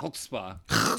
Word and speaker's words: Chutzpah: How do you say Chutzpah. – That? Chutzpah. To be able to pull yourself up with your own bootstraps after starting 0-2 to finish 6-2 That's pretty good.